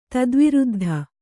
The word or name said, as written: ಧಾತುವೃದ್ಧಿ